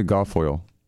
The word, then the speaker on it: Guilfoyle: Was it Kimberly Goit? How you say her name? – Kimberly Guilfoyle. She was screaming though.